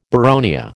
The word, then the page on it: boronia: American English